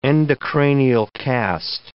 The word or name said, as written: endocranial cast